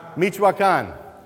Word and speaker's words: Michoacán: Michoacán.